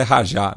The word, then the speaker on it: Raja: que na verdade ele diz que a pronúncia correta é Rajá, né? mas a gente está mais acostumado é, com o Raja Casablanca.